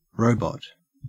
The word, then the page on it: robot: Australian English